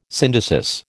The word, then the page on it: syndesis: American English